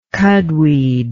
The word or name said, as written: cudweed